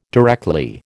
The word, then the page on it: directly: American English